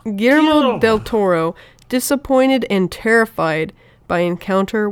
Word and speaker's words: Guillermo del Toro: Guillermo.